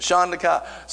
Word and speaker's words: Shandakai: Shandakai. So that sounds awfully religious. But that was the first syllable, Shandakai. And that's all she got. Shandakai. Three syllables. Shandakai.